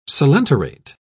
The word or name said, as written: coelenterate